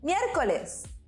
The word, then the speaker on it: miércoles: Miércoles. Did you notice we have an accent there at the E? It's very important to emphasize the E. So it's miércoles, jueves.